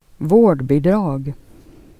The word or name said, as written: bidrag